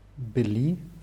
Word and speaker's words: были: Hierbei der Pluralform von были ist die Betonung auf ü wieder.